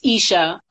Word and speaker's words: Isha: Don't get messed up with the spelling, but just think of Keisha or Aisha without the K and the I. I like saying that, but sometimes people see my name on Instagram or Facebook and they don't want to say it like they see me in person. So it's Isha.